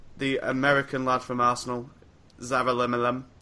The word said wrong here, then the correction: Gedeon Zelalem: Gedeon Zelalem. – Gedeon Zelalem.